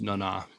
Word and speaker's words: Nana: The correct pronunciation is Nana.